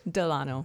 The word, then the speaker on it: Delano: A lot of people accidentally spell it with a D-E, then they say Delano, but with the O, it's more Spanish.